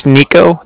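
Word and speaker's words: Niko: It's Nico. That's N-I-K-O.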